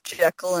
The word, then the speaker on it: Jekyll: Like, it definitely had some, like, adult themes, especially when they get with Jekyll and Hyde and how, yeah.